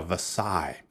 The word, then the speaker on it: Versailles: 叫 Versailles。那个 s 不发音。